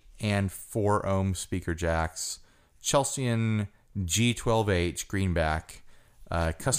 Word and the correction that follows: Celestion: How would you pronounce it? – I'm pretty sure it's Celestion. – Celestion G12H greenback UK speaker.